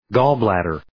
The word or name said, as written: gallbladder